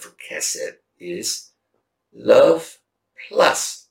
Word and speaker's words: Chesed: The theme of this book is a Hebrew word that is kind of, I give you the word, we pronounce it as Chesed, but it's spelled C-H-E-S-E-D.